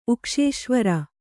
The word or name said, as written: ಉಕ್ಷೇಶ್ವರ